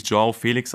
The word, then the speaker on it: João Felix: er heißt, er wird tatsächlich João Felix ausgesprochen, weil der Nachname Felix eben nicht portugiesisch ist. Sonst wäre es natürlich Felix, aber mhm.